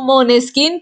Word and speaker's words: Måneskin: Y bueno, digo Måneskin porque esa es la pronunciación correcta.